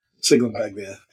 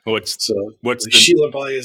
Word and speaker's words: Sciglimpaglia: Especially the American way, like you just said. Sciglimpaglia. Sciglimpaglia is the Italian way.